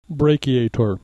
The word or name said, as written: brachiator